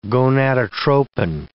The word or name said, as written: gonadotropin